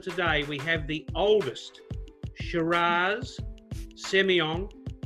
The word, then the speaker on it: Shiraz: In fact, when people think of Shiraz or Syrah, as it's often pronounced by the French, the South Africans, the New Zealanders, uh, and many other parts of the world, the Californians pronounce it as Syrah, but in Australia we call it Shiraz.